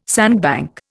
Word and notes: sandbank: Indian

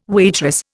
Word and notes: waitress: Indian

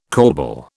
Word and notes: corbel: British English

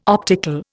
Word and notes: optical: Indian

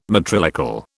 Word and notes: matrilocal: British English

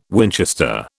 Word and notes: Winchester: British English

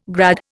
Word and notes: grad: Indian

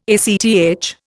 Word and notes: ACTH: Indian

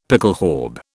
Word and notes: pickelhaube: British English

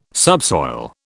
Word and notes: subsoil: British English

